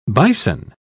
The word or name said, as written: bison